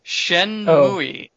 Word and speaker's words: Shenmue: Shmooey. – What? – For the Dreamcast. – What? – Shenmue. – Oh, I heard Shmooey.